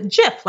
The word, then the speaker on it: GIF: Well, what, so what someone told me is that it was it is GIF, but that the gentleman that actually invented it pronounces it jif like the peanut butter and so i never really